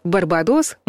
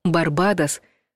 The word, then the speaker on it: Барбадос: Если вы привыкли произносить «Барбадос», можно вас поздравить, вы ошиблись. Правильно ставить ударение на вторую «а» — «Барбадос», а вот прилагательное будет «барбадосский».